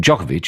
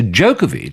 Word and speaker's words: Djokovic: and the changed the pronunciation of his name from Djokovic. He was always known as Djokovic here, to Djokovic.